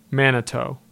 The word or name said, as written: Manitow